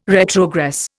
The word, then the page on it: retrogress: Indian